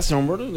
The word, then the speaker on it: Sombr: Escuchamos a Sombr, así se pronuncia Sombr, es realmente para.